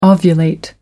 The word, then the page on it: ovulate: American English